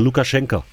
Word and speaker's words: Lukaschenka: dass dann Lukaschenka oder Lukaschenko, wie man, weiß ich nicht, egal. – Also du musst es wie so eine Mischung aus O und A sprechen, Lukaschenka. So wird es ausgesprochen.